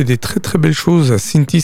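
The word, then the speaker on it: Cinthie: Cinti.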